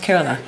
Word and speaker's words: Kerala: It's pronounced Kerala.